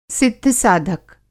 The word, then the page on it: सिद्ध-साधक: Hindi